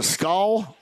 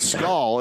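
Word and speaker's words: Skol: It's S-K-O-L, so however you pronounce that. I think it's Skull, right? Skull.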